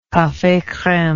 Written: cafe creme

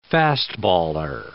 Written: fastballer

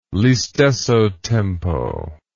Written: listesso tempo

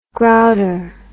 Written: grouter

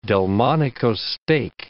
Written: Delmonico steak

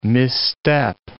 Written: misstep

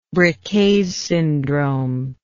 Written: Briquet's syndrome

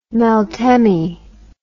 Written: meltemi

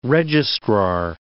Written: registrar

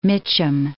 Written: Mitcham

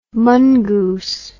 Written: mungoos

